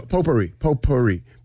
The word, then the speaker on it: potpourri: Potpourri. P-O-P-O-U-R-R-I. – So close, but incorrect. – Was there a U in there? – There's a silent T in there. – P-O-T. – P-O-T. – What? A T? – P-O-T-P-O-U-R-R-I.